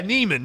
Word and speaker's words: Niemann: Yeah, Niemann, that's how you say it.